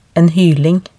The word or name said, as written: en hyling